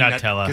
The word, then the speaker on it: Nutella: Technically, right. It should be Nutella. – Nutella? – Because it's derived from hazelnuts, right? So you don't call them a hazelnut.